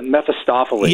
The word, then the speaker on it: Mephistopheles: Mephistopheles. Yeah, I can't say his name. – Mephistopheles.